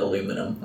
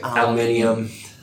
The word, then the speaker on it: aluminium: You still call it aluminum. That's not some pretend that proper English is say over here. – Aluminum's not... How do you say it? – Aluminium.